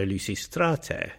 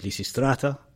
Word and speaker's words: Lysistrate: In this particular case, on the penultimate syllable, so Lysistrate, your voice goes up on the A. – Lysistrate. – Lysistrate. But this then becomes a dynamic accent in modern Greek, Lysistrata.